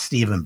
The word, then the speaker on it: Stephan: It's pronounced Stephen, but spelled Stephan, S-T-E-P-H-A-N.